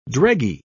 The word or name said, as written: dreggy